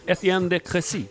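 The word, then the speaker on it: Étienne de Crécy: Uttalar jag rätt? – Ja, det gjorde du.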